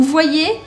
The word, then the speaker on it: voyez: O Y em francês ele tem um som que equivale a dois Is. Então eu falo vous voyez. Por quê? Porque o primeiro I faria a combinação com a letra O e eu falaria wa, wa. E o segundo I, hipotético, eu pronuncio. Então fica vous voyez.